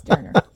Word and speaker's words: Doerner: Yeah, it's Doerner.